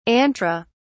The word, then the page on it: antra: British English